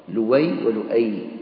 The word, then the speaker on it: لؤي: قال النووي: وقصي بضم القاف، ولؤي بالهمز وتركه، لؤي ولؤي، وإلياس